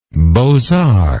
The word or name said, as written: beaux arts